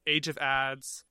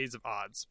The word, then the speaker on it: Age of Ads: Age of Ads, for you, if you want to listen to his more electronic stuff, Age of Ads, it's pronounced actually Age of Odds, but looks like it's Ads.